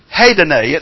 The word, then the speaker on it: hedone: Now, the word for pleasures there is the word hedone. It's spelled H-E-D-O-N-E, and it's the word from which we get the English word hedonism.